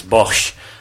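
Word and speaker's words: Bosz: Correct pronunciation is Bosch.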